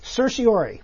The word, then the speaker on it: certiorari: And I'm not going to pronounce the whole word. – I'm going to certiorari. – There we go. You nailed it.